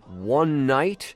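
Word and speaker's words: One Nite: Their 2011 single called One Night, actually written hashtag numeral one and then N-I-T-E, but that is pronounced One Night.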